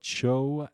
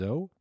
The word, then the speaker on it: Zhou: Not too dissimilar from that of Cho Dynasty, or I guess that's pronounced Zhou, held in the British Museum since 1970, except that instead of tusked breasts, that would be horrified.